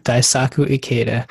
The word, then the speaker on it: Daisaku Ikeda: Daisaku Ikeda. – Oh, Daisaku Ikeda.